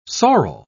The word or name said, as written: sorrel